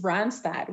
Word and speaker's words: Randstad: Randstad or is it Randstad? – It's Randstad. We're a Dutch-owned company.